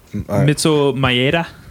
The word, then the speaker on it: Mitsuo Maeda: You said it so wrong, I can't remember how it's actually said. – Yes! we'll just call him Madea, right? – Mitsuo Maeda.